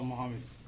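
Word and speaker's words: Mohamed: My name is Nasrullah Mohammed. That is spelled N-A-S-R-U-L-A-H-M-O-H-A-M-E-D.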